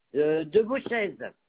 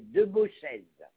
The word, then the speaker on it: Debauchez: De Beauchez. D-E-B-A-U-C-H-E-Z. Olivier. – Vous allez trop vite. D-E-B-H-U. – Non. D-E-B-A-U-C-H-E-Z. De Beauchez. – D-E-B-A-U-C-H-E.